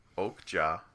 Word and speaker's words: Okja: How would you pronounce O-K-J-A? – Well, I guess Okja, but... – Okay, I'll go with Okja.